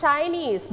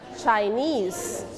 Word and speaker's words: Chinese: Not Chinese, but Chinese. – Chinese. – Very good. We stayed on the second syllable.